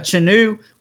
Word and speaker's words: Chenoo: And that's spelled C-H-E-N-O-O or C-H-E-N-U. – That way it's easier to pronounce it because it looks a little bit more how it should be said.